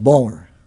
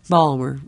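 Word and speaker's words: Baltimore: So there's a tendency for all cities to try to contract the name into a single syllable, and sometimes they make it like Balmer. – Balmer, right.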